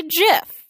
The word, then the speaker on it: GIF: The pronunciation of this internet-centric word for a moving image is highly contested. And what is, of course, a GIF? Or, in some worlds, a GIF. Or a GIF.